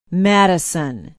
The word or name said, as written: Madison